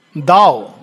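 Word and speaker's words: Tao: नहीं, ताओइज्म एक अलग चीज है, पर बुद्धिज्म, ताओइज्म उपनिषदिक है, दाओ, हाँ वो दाओ, नॉट ताओ, दाओ, वो प्रोनाउंसिएशन में दाओ कहते हैं, लिखने में ताओ जैसे ताओ हो जाता है, लेकिन इट इज प्रोनाउंस्ड एज दाओ, so it is an Upanishadic saying.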